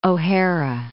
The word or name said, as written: O'Hara